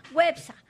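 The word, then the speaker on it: Güepsa: ¿Cómo se dice a los que nacieron en Huespa? Perdón, Huepsa. Websa. ¿Ah? Con diéresis.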